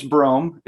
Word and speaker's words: Brom: My first name's Brome. It's spelled B-R-O-M, but it's a long O, like the city Rome with a B.